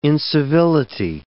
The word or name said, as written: incivility